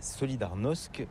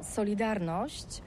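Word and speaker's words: Solidarność: Alors solidarité, en français on dit Solidarnosc, mais ici à Gdansk on ne prononce pas Solidarnosc. – Solidarnosc.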